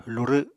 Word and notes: lurw: Apatani

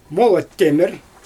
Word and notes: molat temIr: Xyzyl

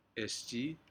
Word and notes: esjii: Aneityum

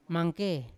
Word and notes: mangke: Yawa

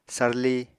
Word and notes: sarli: apatani

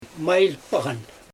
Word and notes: majɪj poɣan: Xyzyl

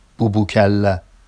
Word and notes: bubucalla: Vafsi

